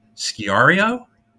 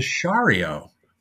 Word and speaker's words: Sciarrio: Here's how he spells it. S-C-I-A-R-R-I-O. Now, I said, right. – Sciario, and he went, and told me it was Shario, which would be a great, actually, if you use that one name, you could be a like a romance novel model.